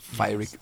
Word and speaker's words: Pyrrhic: Is that how it's pronounced? Pyrrhic victories. – Pyrrhic. CH. – Yes, right. Pyrrhic victories.